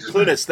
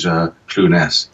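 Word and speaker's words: Clunes: But yeah, I don't think it's a right or wrong way of saying that. But I think he chooses to use Clunis. – Clunis.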